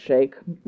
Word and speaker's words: sheikh: But I learned within the last 10 years that it was pronounced Sheik.